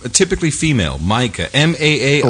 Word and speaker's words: Maaike: But let's start with one from the Netherlands, because you're going to have to help me pronounce a $75 donation from, it's spelled M-A-A-I-K-E. – Micah. – Is it Micah? Is that Micah.